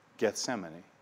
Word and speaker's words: Gethsemane: If we were to take the Hebrew words for, oil, and press, and put them together, we would pronounce them, in English, we would say Gethsemane. Gethsemane.